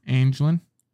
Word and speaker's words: Anglin: A-N-G-L-I-N. – England? – Angelin. And how would you say it? – It's Anglin.